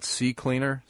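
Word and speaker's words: CCleaner: It's called Sea Cleaner. So it's spelled C and then C-L-E-A-N-E-R. And the company is Piriforms, P-I-R-I-F-O-R-M-S.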